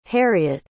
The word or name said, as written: harriet